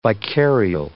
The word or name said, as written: vicarial